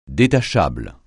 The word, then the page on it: détachable: francais